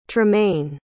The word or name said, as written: tramaine